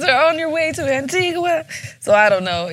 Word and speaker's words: Antigua: Did I say that right? Antigua or Antigua? – When you're there, they say Antigua. – Antigua. – But, you know, the flight attendants be like, Antigua.